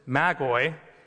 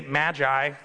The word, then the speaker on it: magoi: The Greek word is magoi, which is plural for magos. But you can say magi if you want. I'm not going to get mad at you, but that's how it's actually pronounced, magoi.